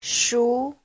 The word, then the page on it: shu: shu2